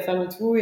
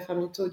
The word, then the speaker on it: Farmitoo: et à bien prononcer euh, Farmito et pas, euh, et pas Fermito, du coup. – Farmito ouais, donc le, d'où le double O à la fin.